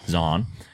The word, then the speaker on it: Zahn: I do like Steve Zahn or Zahn or whatever, however it's pronounced. – Zahn.